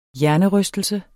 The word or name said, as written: hjernerystelse